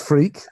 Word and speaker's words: Freke: Well, they can find me because my name is strange, F-R-E-K-E, but pronounced freak.